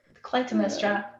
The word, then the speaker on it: Clytemnestra: am I saying her name right? Clytemnestra. Clytemnestra. That's how we're saying it. Okay. Clytemnestra.